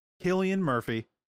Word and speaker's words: Cillian Murphy: Cillian Murphy, because I've been saying that wrong too.